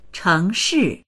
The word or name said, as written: cheng2shi4